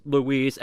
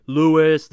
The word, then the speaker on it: Luis: Because I tell you this, my name is Luis, L-U-I-S. I've always had, like, Louis the American way, or L-O-U-I-S.